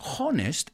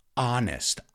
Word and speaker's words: honest: It's not honest, it's honest. Ah.